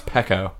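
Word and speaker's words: pekoe: It's just orange pico. – Uh-huh. You know it's actually pronounced peco.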